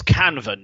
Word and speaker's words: Canva: It's Canva, Nick.